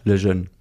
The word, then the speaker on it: Lejeune: Erich, wie spricht man das aus? – Lejeune.